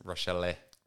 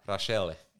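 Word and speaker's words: Rachele: And I think it's pronounced Rochelle. – Rochelle? – Yeah, the Italian pronunciation of it. – You get to flick your wrist a little bit when you say it as well.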